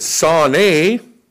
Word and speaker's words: sane: Now, you look up that word in Hebrew, and in Hebrew, that word, you pronounce it sane, and here's what it means, and it's only meanings, to hate, personally, an enemy, a foe, odious.